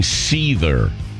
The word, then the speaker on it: Seether: we open the national calendar, yes, for everyone who asks, she's saying Seether, S-E-E-T-H-E-R, like a snake.